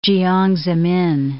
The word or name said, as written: Jiang Zemin